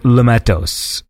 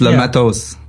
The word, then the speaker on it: Le Matos: How do you say it? Because I say Le Matos, but like, is it Le Mato? – No, you're close enough. It's Le Matos.